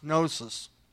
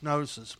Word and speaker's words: gnosis: It's pronounced gnosis. It actually starts with a G, but it's pronounced gnosis because the G is silent.